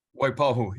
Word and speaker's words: Waipahu: He spent some time as the head coach at Waipu High School. Did I say that right, Coach? Waipahu, yeah. Waipahu. Close enough.